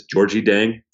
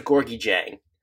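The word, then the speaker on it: Gorgui Dieng: I never know how to pronounce this. Georgie Deng. – I suck at pronunciations. You know this. I'm pretty sure it's Gorgie Jang. It changes when you're watching away broadcasts, but I think that's the proper pronunciation. – I'm looking it up now on Basketball Reference, and you are correct.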